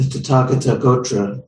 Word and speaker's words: Tathagata Gotra: The Tathagata Gotra is how it's said in Sanskrit.